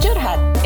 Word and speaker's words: curhat: It's pronounced curhat. – It's an Indonesian term.